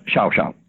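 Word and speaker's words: Llao Llao: In Argentine, in Argentine Spanish, it's Chow Chow.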